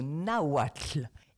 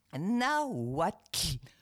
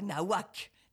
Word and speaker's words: nahuatl: Maintenant, on passe au nahuatl. Écoute-moi bien. Nahuatl. Pas nahuac. Nahuatl. – Mmh. – Alors, c'est très agréable à prononcer. T'as un petit souffle qui sort de la langue.